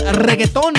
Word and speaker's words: reggaeton: So, Bill did this reggaeton mix. – Yeah. – How do you say it? – Reggaeton. – That's right. You got to roll your R's, baby.